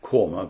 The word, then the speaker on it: Quernmore: He died in 1855 in a place called Quorma in Lancashire. This place is called Quorma, but it's spelled Q-U-E-R-N-M-O-R-E. He died.